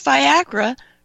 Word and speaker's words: Viagra: And if you'll notice, Viagra rhymes with Niagara.